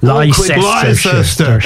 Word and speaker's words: Leicester: Leicester, and sure. Derby. – And the other one is Cirencester. Because Leicester... And Cirencester, same spelling, totally different pronunciation.